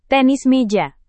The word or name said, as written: tenis meja